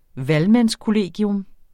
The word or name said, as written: valgmandskollegium